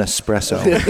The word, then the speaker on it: espresso: The best was earlier when Dave offered our guest an espresso, and he said, "Oh, I would love an espresso." So that has now been solved, I think, as a conundrum which way to pronounce it.